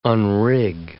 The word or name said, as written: unrig